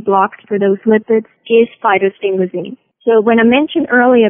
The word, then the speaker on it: phytosphingosine: Yes, phytosphingosine, or for obvious reasons, we oftentimes refer to it as simple PS.